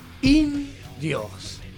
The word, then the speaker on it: In-Dios: Indios. – Acento en la O. Dios, de Dios, dentro del universo, dentro de esta cosa rara que hay acá.